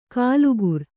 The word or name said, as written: ಕೂಲಿಕಾರ